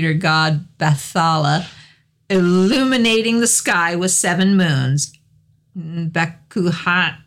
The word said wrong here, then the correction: Bakunawa: I'm going to just butcher this name. – Bakunawa. – That's the one.